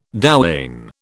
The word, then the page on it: douane: British English